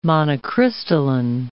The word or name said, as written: monocrystalline